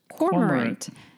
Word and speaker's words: cormorant: Kind of like cormorant. And then I looked it up and I was like, holy shit, there's an R after the O. So it's cormorant.